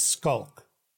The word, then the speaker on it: Schalk: And so the way we pronounce it is the CH becomes a K. So it's Skulk.